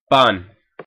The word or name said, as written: pan